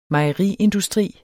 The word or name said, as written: mejeriindustri